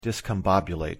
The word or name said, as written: discombobulate